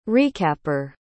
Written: recapper

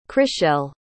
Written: chrishell

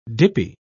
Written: dippy

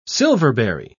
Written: silverberry

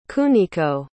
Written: Kuniko

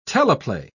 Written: teleplay

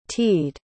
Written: teed

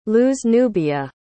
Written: luznubia

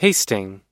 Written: pasting

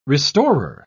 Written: restorer

